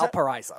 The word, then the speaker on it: Valparaiso: Uh, being recruited, I, I, see you were recruited by two schools, Central Michigan and Valapar- what was Valparaiso.